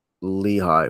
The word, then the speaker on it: Lehigh: And this week we got Camp Lehigh, which I believe is the correct pronunciation.